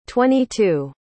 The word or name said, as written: twenty-two